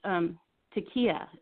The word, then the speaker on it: taqiyya: And it's interesting that you use the word taqiyya. Am I saying that correctly? – Yes.